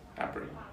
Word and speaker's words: Gnabry: So it's K-N-A-U-F-F. In the same way that you say, would you say Serge Gnabry or Serge Gnabry? – Gnabry.